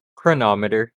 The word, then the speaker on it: chronometer: a faulty chronometer, if I pronounce that correctly. – Let me see, where are you highlighted? Chronometer. – Okay, I said it correctly. Thank you.